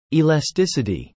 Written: elasticity

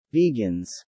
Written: vegans

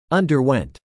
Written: underwent

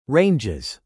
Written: ranges

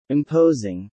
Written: imposing

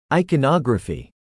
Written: iconography

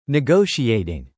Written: negotiating

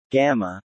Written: Gamma

